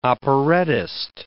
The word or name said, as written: operettist